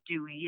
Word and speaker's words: Duez: I am Lisa Dewey. My name is spelled D-U-E-Z, but it's pronounced Dewey.